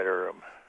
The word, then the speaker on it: Urim: and then some of my cross-reference scriptures led back to Exodus and Numbers, and the one in Numbers, it's talking about the Urim. Is that how yes. – am I saying that right? – Yeah, the Urim and the Thummim, yeah.